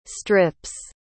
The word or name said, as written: strips